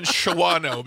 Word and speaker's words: Shawano: How do you pronounce it? – Shawano? – I say Shawano. – Do you know that my dad and stepmom live there? – Yeah. I didn't know that, but they live in Shawano because sorry, I'm a child of phonics. – No, it's pronounced Shawano.